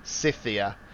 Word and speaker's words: Scythia: Herodotus, the Greek historian, he reported that the inhabitants of Scythia, how do you say that again? – Scythia, would often inhale the vapors of hemp seed smoke in ritual use and for pleasurable recreation.